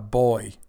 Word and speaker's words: buoy: And we call it B-U-O-Y. We are actually kind of wrong here. We call it a boy. Not really a boy, is it? – Where's the U? Where's the U pronouncing? Buoy.